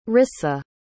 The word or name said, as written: rissa